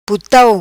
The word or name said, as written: Putau